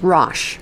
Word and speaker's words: Rosh: And it looks like the pronunciation is Rosh.